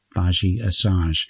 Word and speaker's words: Badi Assad: Her name is spelt B-A-D-I-A-S-S-A-D, Baji Asage.